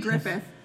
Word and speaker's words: Gruffudd: The film stars Ewan Gruffudd. I'm assuming I'm pronouncing that correct. – It's Ewan Griffith. – Okay, because it's not Ewan Gruffudd.